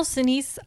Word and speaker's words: Sinise: It says, though in general Sinise, am I saying his name right? – It sounds right. Okay. To me.